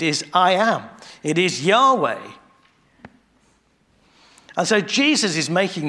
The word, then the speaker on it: Yahweh: So in the Old Testament, that's just four letters, which you can pronounce Yahweh, or sometimes another way.